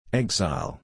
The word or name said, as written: exile